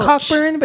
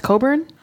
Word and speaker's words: Cockburn: Like it's spelled Cockburn, but it's pronounced Coburn.